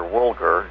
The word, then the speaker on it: Woolger: Roger Woolger, W-O-O-L-G-E-R, but it's pronounced with a hard G, an organization called Woolger International.